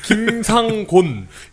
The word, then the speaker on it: 김상곤: 장학대단과 경기 고교 평준화 시민연대 같은 곳에서 일하기도 했고 김상공 김 김상공 김상곤 예.